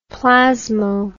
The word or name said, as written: plasmo